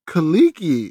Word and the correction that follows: Kuechly: is what you said. – What's his name? – Luke Keekley. – Oh, I butchered that name.